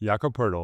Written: Jakob Poeltl

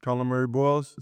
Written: Collin Murray-Boyles

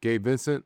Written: Gabe Vincent

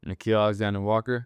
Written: Nickeil Alexander-Walker

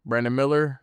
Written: Brandon Miller

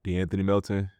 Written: Deanthony Melton

